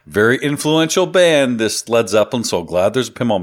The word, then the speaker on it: Led Zeppelin: And he spelt lead LED because, and this is true, he didn't want North Americans, he didn't want them to pronounce it Lead Zeppelin, so he changed it to Led Zeppelin.